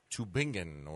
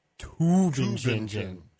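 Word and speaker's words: Tübingen: U is, they said it's like room, like, you know, like the O's in room. So it's like Tubingen.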